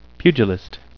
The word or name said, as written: pugilist